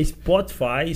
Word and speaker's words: Spotify: Spotify? Spot, é que evite corrigir o inglês aqui, né? você uhum. fala ah. Spotify. – É Spotify, Spotify.